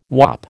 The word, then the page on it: whop: American English